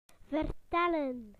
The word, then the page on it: to tell: British English